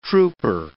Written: trooper

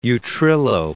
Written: Utrillo

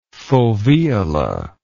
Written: foveola

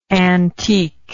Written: antiqueness

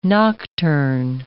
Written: nocturne

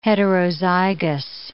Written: heterozygous

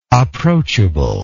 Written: approachableness

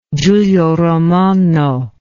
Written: Giulio Romano

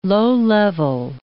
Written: low-level

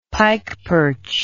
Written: pikeperch

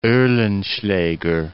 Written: Oehlenschlager

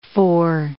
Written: fore

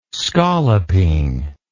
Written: scalloping

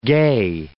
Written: gey

